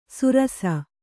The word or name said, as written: ಸುರಸ